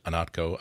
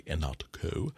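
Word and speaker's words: Ihnatko: I'm just Anatko, I-H-N as in Nancy, A-T-K-O. Welcome to the club of people that can pronounce and spell my name correctly, Anatko.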